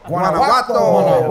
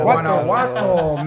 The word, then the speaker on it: Guanajuato: Also, with them today is Los Farmers, consistency of Fernando Santoya, Kenny Salgado, Juan Carlos Cisneros, and Jesus Gonzalez, a band from Guanajuato, Did I say that right? – Guanajuato. – Guanajuato, Mexico, best known for their classy chingons like El Mas Bascalo, La Changa, El Gabalan.